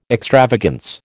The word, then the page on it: extravagance: American English